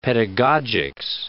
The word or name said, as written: pedagogics